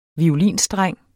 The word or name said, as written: violinstreng